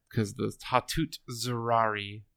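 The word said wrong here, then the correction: Zeraze: or Zarazi, sorry, I said that wrong.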